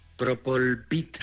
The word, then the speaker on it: Propolvit: Recordamos, Propolvet, lo he dicho bien, Defense. – Propolvit.